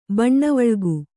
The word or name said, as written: ಬನವಳಿಗ